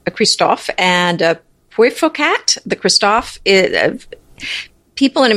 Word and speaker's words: Puiforcat: and the cat is a P U I F O R C A T.